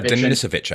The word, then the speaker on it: Denisovich: I think it's Ivan Denisovich. – Denisovich, I think. – Denisovich, yeah, there you go.